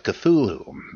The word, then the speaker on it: Cthulhu: writes, on episode 6, you talked about the correct pronunciation or pronunciations of Cthulhu. I just wanted to point out a possibly interesting, possibly random connection I made there. You said Lovecraft said that the first syllable was sort of like clue,